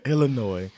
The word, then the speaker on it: Illinois: Illinois. You know what? You know how I know? It's because the S is silent.